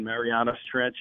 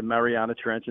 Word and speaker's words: Mariana Trench: Yeah, there's no wrong way to pronounce it. – Right. – I was saying Marianas Trench, and then I was corrected to Mariana Trench, and then somebody else said, no, it's either or, so you're good.